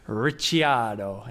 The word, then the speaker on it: Ricciardo: Now the problem is how I was pronouncing it was also wrong. So I'm now going to have to call him Daniel Ricciardo. And you have to say it like that as well.